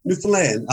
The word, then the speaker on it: Newfoundland: It's pronounced Newfoundland, right? – Newfoundland. I always tell people, you say, understand Newfoundland.